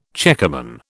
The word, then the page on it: checkerman: British English